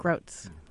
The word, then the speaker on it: Grotz: She won the National Book Critics Circle Award, but she's this magnificent musical lyric poet, K-A-S-I-S-C-H-K-E. – Rachel? – I hope I don't say her name wrong. Is it Jennifer Grotz? – Grotz, I think. – Grotz.